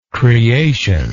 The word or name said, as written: creationary